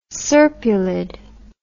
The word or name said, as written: serpulid